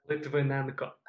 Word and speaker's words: Lytvynenko: Lytvynenko.